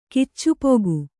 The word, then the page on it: ಕಿಚ್ಚುಪೊಗು: Kannada